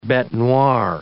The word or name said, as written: bete noire